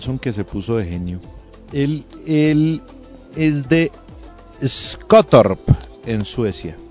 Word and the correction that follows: Skottorp: Hable bien sueco. – Perdón, sí, pero no pronuncié tan bien. Pronuncié más como danés. – Skottorp. – Skottorp. Sí, es cierto. Sí, tienes razón. Gracias.